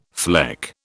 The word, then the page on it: fleck: British English